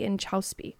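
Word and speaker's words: Inchauspé: How do you pronounce her last name? – I forget. – Inchausby. I believe is how you say it. Jesse Inchausby wrote this fantastic book called The Glucose Revolution.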